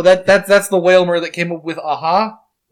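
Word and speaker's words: Wailmer: I still pronounce that Pokemon Whamler, even though it's Whalmer.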